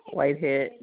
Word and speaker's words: Whitehead: T-Y-W-A-N-D-A. – Hi, girls. – Okay, T-Y-W-A-N-D-A. Ms. Tawanda, what's your last name? – Whitehead, W-H-I-T-E-H-E-A-D. – Thank you. W-H-I-T-E-H-E-A-D.